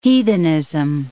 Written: heathenism

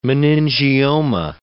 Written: meningioma

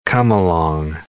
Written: come-along

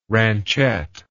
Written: ranchette

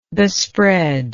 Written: bespread

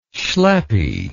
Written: schleppy

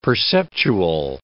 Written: perceptual

